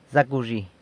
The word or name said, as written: Zacawzhi!